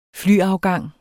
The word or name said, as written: flyafgang